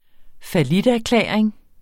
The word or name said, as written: falliterklæring